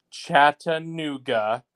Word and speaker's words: Chattanooga: And I just want to put it on record, League One, Chattanooga, four syllables. Four syllables.